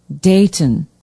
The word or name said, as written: Dayton